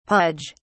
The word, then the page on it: pudge: British English